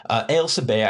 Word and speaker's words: Ailsa Bay: Ailsa Bay, I'm kind of, that is the correct pronunciation, I believe, but it is spelled like A-I-L-S-A, and that's not how, maybe if you're looking in the store, you're going to be trying to find Ailsa, like I'm saying it, but that is how it's spelled.